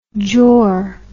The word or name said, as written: jor